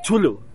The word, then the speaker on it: Cthulhu: ¿Cómo se dice? ¿Cthulhu? ¿Chulu? – Chulu. Se dice Chulu. – Pero ese quién lo ha dicho. – Esa es la versión oficial que todo el mundo ha determinado. Porque así es como se pronunciaría en su idioma, que bueno, es como un estornudo.